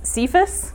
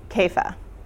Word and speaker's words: Kepha: There's two ways of pronouncing it. One is Cephas, when we use that name in America. Cephas is the pronunciation we use. But another way to pronounce it is Kepha. So that is the Aramaic word for rock.